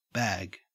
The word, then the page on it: bag: Australian English